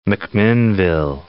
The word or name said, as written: McMinnville